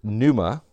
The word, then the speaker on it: pneuma: Now the word for spirit is the word that is, how do you pronounce it? It is pneuma. The word for soul is psyche, or P-S-U-C-H-E in the Greek.